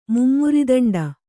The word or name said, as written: ಮುಮರಿದಂಡ